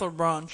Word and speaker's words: Hebron: But like I said, it's in South Charlotte on South Hebron Street. I don't know how you say it. – South Hebron Street. – Hebron Street. H-E-B-R-O-N. – L-E-B-R-O-N.